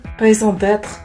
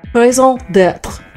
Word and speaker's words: raison d'être: I tried raisin de temps. How did you pronounce it again? – Raison d'être. – Raison d'être. – Let me pronounce that clearly. Yes. – R on the end of it.